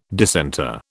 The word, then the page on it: dissenter: British English